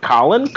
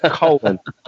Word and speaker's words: Cullen: Every time I, like, this is Cullen, they're like, Colin? Cohen? Like, no. Cullen.